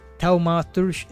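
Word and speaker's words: Thaumaturge: y se trata de, de de Taumaturge, eso mismo, así se pronuncia.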